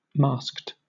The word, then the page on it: masked: British English